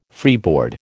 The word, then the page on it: freeboard: American English